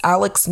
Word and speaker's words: Alex: I know I was definitely confused because it's spelled like Alex, but for some reason he pronounces it as Alec, almost as if there's a C at the end instead of an X.